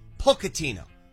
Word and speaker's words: Pochettino: Okay, in Italian, a CH is pronounced K. – Not CH, not CH. It's K. Pochettino.